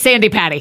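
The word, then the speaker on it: Sandi Patty: It's Sandy Patty.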